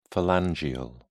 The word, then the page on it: phalangeal: British English